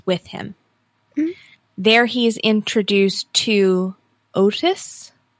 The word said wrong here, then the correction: Otha: Is that how they pronounce it in the audio? – Otha. – Otis? Otha. Otha and Robert.